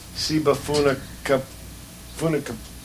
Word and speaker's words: Sibafunikabuleta: Say it again. – How would you say that? By kyoka. How would you say that? – I have to see it. – Sibafunikabuleta. – Exactly. That's it.